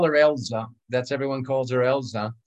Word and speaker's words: Elsa: You know, I called her Elsa for the first 6 months I knew her, and I realized, oh no, they pronounce it Elza over there.